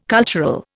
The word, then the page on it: cultural: Indian